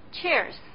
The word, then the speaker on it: cheers: Cheers，干杯。 – Cheers，干杯。不过这好像跟喝的关系更大一点。 – 哎呀，我是让你注意它的发音。 – 哦。 – 后面跟的是 idea 里的 ear。